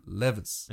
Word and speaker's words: Levis: Ich kann es nie aussprechen, weil ich immer Lewis sagen will, aber er heißt Will Levis.